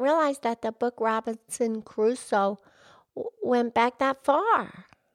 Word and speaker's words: Crusoe: No, it's just Crusoe. Two syllables.